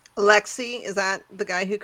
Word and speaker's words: Alexey: Alexei, A-L-E-X-E-Y is how it's Alexei, yeah.